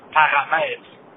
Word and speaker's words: parameter: I'm actually a native French speaker who was born in the province of Quebec, and the parameter pronunciation is something that a lot of native French speakers get wrong, because when they read it, the intonation of how you pronounce it in French is paramètre. When people kind of translate letter, you know, speaking in English, I've had a couple of colleagues, because I work in engineering, say parameter to English-speaking colleagues at some point.